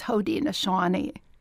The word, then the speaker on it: Haudenosaunee: Uh, it, she was a member of the Iroquois tribe, but its true pronunciation is Haudenosaunee.